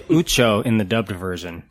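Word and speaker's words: Uco: Ever say his name clearly, but at the end, they I believe in the language it's Ucho, because the martial art is spelled Pensac, and it's Penchak, so I'm guessing the C makes a ch sound in Indonesian.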